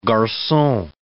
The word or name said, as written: garcon